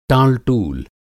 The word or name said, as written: टाल-टूल